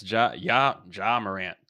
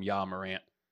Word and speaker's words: Ja Morant: I had it right and I still called him Ja Morant. I don't know why I go with a Spanish pronunciation for it. I don't even speak Spanish.